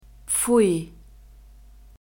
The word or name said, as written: pfui